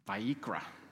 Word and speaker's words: Vayikra: But if you were to go to a Jewish synagogue today, they would be reading from the book of Vayikra.